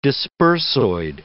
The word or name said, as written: dispersoid